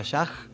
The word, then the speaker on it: ASHAJ: A estar todo publicado en el Facebook, que poniendo Ayaj en el buscador aparece, es A-S-H-A-J. Repito por las dudas, A-S-H-A-J, por eso se pronuncia Yaj.